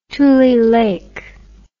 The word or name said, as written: Tulelake